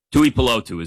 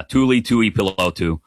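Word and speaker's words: Tuipulotu: Tui Pelotu is how you say it. But yeah, Tui Pelotu.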